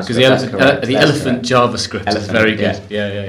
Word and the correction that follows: Eloquent JavaScript: Elephant. I didn't realize how easy it is to mispronounce that word. – Yeah. Especially when you're recording yourself. – Mm. – Eloquent JavaScript is great.